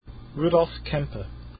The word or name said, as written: kempe